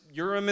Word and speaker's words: Urim: And if you look, um, if you look up what the Urim and the Thummim is, I know it's weird pronunciation there, but it's Urim and Thummim.